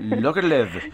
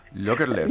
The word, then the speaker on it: Lagerlöf: Pues para aligerar el ambiente electoral les voy a recomendar una novela de Selma Lagerlöf, Lagerlöf, tú lo sabes pronunciar mejor que yo. Eh, Lagerlöf, Lagerlöf.